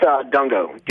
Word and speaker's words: Dungo: It's Dungo. D-U-N-G-O. – D-U-N-G-O.